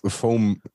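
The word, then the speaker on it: FOAMed: Ihr seid vernetzt miteinander und ihr seid auch aktiv, ähm, was, ähm, was diese Vormed betrifft. Habe ich das richtig ausgesprochen? – Foam, würde ich sagen.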